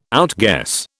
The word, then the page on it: outguess: British English